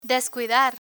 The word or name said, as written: descuidar